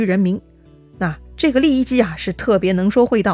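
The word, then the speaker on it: 郦食其: 另一个说的是刘邦手下的一个著名的能言善辩之人，叫利益姬。利益姬这三个字啊是比较特殊的，利呢是美丽的丽，右边加上双耳旁；益呢其实就是食物的食那个字，这个食啊是多音字，有三个音，益呢就是其中的一个了，用于人名。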